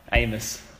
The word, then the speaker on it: Amos: Jai Amis. Amis. How do you say it? – Amos. – Okay, Amos. Jai Amos. He kicked two snags.